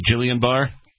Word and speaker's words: Guillain-Barré: Guillain-Barré? – Yeah.